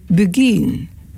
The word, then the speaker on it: beguine: En je spelt het B-E-G-U-I-N-E. De Bulgarian. Zo spreek je het uit.